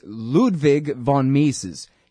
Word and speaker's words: Ludwig von Mises: See, I said it wrong my whole life, but now I got to get it right. It's Ludwig von Mises.